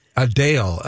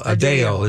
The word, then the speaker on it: Adele: Or however you want to pronounce it. Adele. Adele is how she wants it. But we don't have British accents, she's Adele. – Yeah. – If we were British, she'd be Adele.